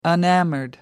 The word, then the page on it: enamored: British English